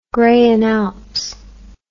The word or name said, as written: graian alps